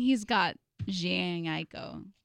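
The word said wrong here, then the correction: Jhené Aiko: Janae Aiko.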